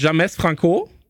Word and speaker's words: James Franco: Okay, mit James Franco ist das Ganze auch noch. – Absolut richtig, so spricht man den auch.